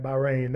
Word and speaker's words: Bahrain: Was at Bahrain for a while, and then I think I said that right, Bahrain.